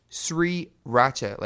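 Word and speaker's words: 3RACHA: And they're known as Sri Racha, like three, the number three, Racha. And in Korean, you pronounce three as siri. So I guess technically, sriracha, like the hot sauce, which is amazing because I really like hot sauce.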